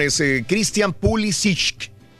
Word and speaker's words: Pulisic: Pulisic, Pulisic. Pulisic, es correcto.